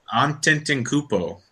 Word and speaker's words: Antetokounmpo: Antetokounmpo.